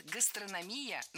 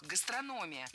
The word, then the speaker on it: гастрономия: Много раз мы услышали вариант «гастрономия», но это неправильно. Ударение в этом слове падает на третий слог – «гастрономия».